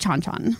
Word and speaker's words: Chan Chan: well, I decided to talk about this article is because this is a place that I've been and I remember it being pronounced Chanchan.